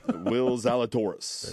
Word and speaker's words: Will Zalatoris: And both mispronounced it. So. – Exactly. Will Zalatoris.